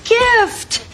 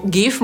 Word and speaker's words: GIF: Podsumowując, nazwę pliku GIF możemy po angielsku przeczytać na dwa sposoby. Albo jako GIF, albo jako GIF. I oba te warianty podawane są w słownikach jako poprawne, ale twórca tego formatu zachęca, by wymawiać to jako GIF. Mnie bardziej logiczna wydaje się wymowa GIF, ponieważ litera G w tym skrócie pochodzi od słowa graphics, czyli grafika.